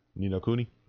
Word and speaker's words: Ni No Kuni: Best role-playing game was Ni No Kuni 2, Revenant Kingdom. Okay. Did I say that right? – Ni No Kuni? – Mm-hmm.